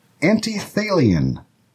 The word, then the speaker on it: antithalian: It is antithalian. Antithalian. That is spelled A-N-T-I-T-H-A-L-I-A-N.